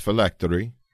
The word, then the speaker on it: phylactery: Phylamery is? – I just said it. It's phylactery.